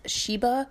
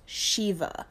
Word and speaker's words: Shiva: I mentioned the god, I thought it was pronounced Sheba, but it's pronounced Shiva.